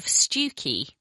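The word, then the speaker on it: Stiffkey: So actually, Stewkey is written Stifkey.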